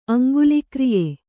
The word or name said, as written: ಅಂಗುಲಿಕ್ರಿಯೆ